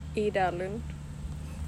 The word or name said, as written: Ida Lundh